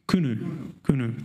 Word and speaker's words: Qunu: South Africans might be able to help me with that pronunciation. Kunu, thank you.